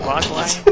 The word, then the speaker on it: Mogwai: And you had the cute gizmo, the Mogwai? Is it Mogwai how you pronounce it? – Yes, it's Mogwai.